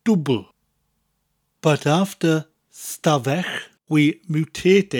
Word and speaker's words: dwbwl: Now, double in Welsh is dwbl, dwbl. It's spelt D-W-B-W-L, but we drop the last W in conversation. So instead of saying dwbl with two O sounds, we just say dwbl.